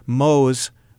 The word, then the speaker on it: Mohs: And I spend my days doing something called Mohs micrographic surgery. It's named after a guy named Frederick Mohs who invented it, and it's spelled M-O-H-S. A lot of people think I do mole surgery, you know, like like the brown moles we have in our body.